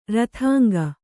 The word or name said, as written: ರಥಾಂಗ